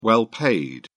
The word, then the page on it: well paid: British English